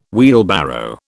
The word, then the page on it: wheelbarrow: British English